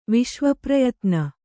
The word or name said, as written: ವಿಶ್ವಪ್ರಯತ್ನ